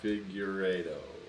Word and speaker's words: Figueiredo: Figueiredo.